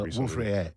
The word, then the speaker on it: Wolf-Rayet: Wolf-Rayet. R-A-Y-E-T. – Okay. – If it's French, you don't pronounce the trailing consonant. – Right, so Wolf-Rayet. Nice.